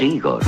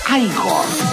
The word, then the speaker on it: Igor: Usted debe de ser Igor. – No, se pronuncia Igor.